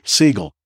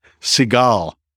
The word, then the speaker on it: Siegel: And he would not pronounce his name Siegel. Refused to. He pronounced it Seagal. like Steven Seagal.